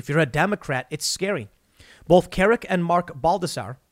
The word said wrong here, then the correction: Baldassare: Baldassare, I pronounce it,